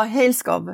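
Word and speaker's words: Hejlskov: Eh, du tar ju eh, det här exemplet med, nu vet jag vet inte hur man uttalar hans namn, Bo... – Ja, Helskov. – Helskov. – Ja.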